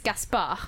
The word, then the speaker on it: Gaspard: The French pronunciation is Gaspard.